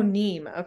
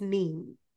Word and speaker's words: Niamh: In the book, I thought it was Neve, but the way it's spelled is like Neem. – Oh, Neem. – It's almost spelled like Nima. It's spelled NIAMH. – Oh, okay. – So I'm assuming since I was hearing Neve, it's Neem.